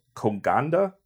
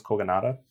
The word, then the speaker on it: Kogonada: Um, Koganda, Kogonada, I think it's Kogonada.